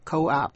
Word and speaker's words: coop: Còn COOP đọc là coop, nghĩa là chuồng gà, phát âm là coop.